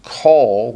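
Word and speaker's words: kol (כל): And notice in the middle you have the word kol, or when it's by itself it's pronounced kol, which means all, right?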